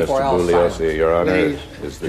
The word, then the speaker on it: Bugliosi: And the two attorneys constantly jab at each other, like when Spence mispronounces Bugliosi's name. – The name is pronounced Bugliosi. The G is silent.